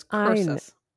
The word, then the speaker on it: Cursus: It starts with a C. It sounds like Circus Publicus, but it's Cursus.